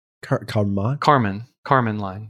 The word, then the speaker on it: Kármán line: So the billionaire Richard Branson went to 53.5 miles into the sky on Sunday, short of the, how do you pronounce that? Kármán line? – Kármán line. – Kármán line, okay.